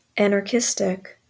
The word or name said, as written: anarchistic